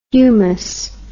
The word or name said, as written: humous